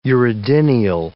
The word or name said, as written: uredinial